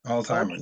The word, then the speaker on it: Altheimer: Altheimer.